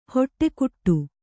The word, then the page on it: ಹೊಟ್ಟೆಕುಟ್ಟು: hoṭṭe kuṭṭu